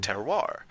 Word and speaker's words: terroir: terroir, yep. – Which is weird because it's spelled T-E-R-R-O-I-R, and yep. but it's pronounced with a W in the middle, so...